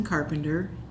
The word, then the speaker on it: Carpenter: Okay, Susan Carpenter, C-A-R-P-E-N-T-E-R.